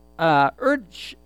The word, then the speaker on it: Edersheim: Alfred Edersheim is how you say it, found 456 Old Testament verses relating to the Messiah.